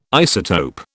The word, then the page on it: isotope: British English